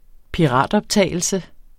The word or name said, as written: piratoptagelse